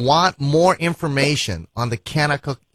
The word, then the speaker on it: Kanakuk: Kennecook is spelled K-A-N-A-K-U-K. Kennecook. Everything with a K there.